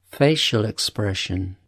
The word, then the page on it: facial expression: British English